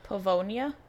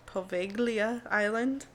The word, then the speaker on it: Poveglia: It's spelled P-O-V-E-G-L-I-A. – Pavonia? – There's no N. – Yeah, but the G is like baloney.